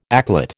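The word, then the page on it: éclat: American English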